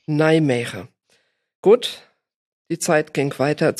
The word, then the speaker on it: Nijmegen: Das ist in Nijmegen, habe ich das richtig ausgesprochen, in Holland? – Nijmegen. Oder Nijmegen auf Deutsch. In Deutschland sagt man, glaube ich, eigentlich Nijmegen.